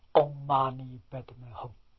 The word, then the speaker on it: Om Mani Padme Hum: 经题上清净平等住，阿弥陀佛是觉，六字大明咒是清净，这六个字发音是 Om Mani Padme Hum。